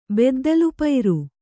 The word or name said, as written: ಬೆದ್ದಲುಪೈರು